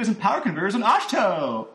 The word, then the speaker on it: Ahch-To: Opens up with, fade from black onto the island of, is it pronounced Ahch-To? Ahch-To? – It's A-H-C-H-T-O. – Ahch-To? – Ahch-To?